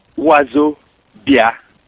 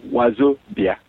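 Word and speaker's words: Wazobia: Now, I hope that's easy enough and phonetic enough for people to pronounce. I didn't want it to be. It's African based. But I wanted to use simple African pronunciations that people can follow, so it wouldn't be too intimidating. It's called Wazo Bia.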